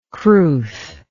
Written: crwth

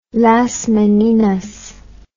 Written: Las Meninas

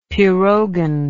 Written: pirogen

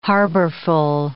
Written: harborful

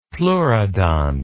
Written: pleurodont

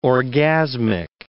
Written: orgasmic

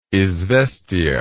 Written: izvestia

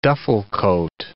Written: duffle coat

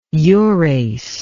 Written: urase

